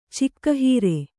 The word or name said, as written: ಚಿಕ್ಕಹೀರೆ